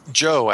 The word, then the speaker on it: Zhou: The story of, is it Zhu? Is that how you pronounce it? Or Joe, actually. Joe. – Oh, I was close. – Z-H-O-U, yeah. That's his family name, and it's Joe.